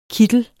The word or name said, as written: kittel